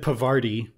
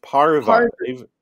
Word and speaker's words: Parvati: Not Pavarti. But it's par. It's Parvati. Parvati. – That's right. Yeah, Parvati.